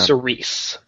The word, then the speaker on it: Cerise: So Alex, you notice that Cerise, or is it Cerise or Cerise? I'm saying it wrong every time. – Cerise. C-E-R-I-S-E.